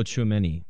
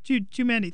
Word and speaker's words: Tchouaméni: Yeah, I just butchered his name. – Oh, Tchouaméni. – Tchouaméni. Thank you.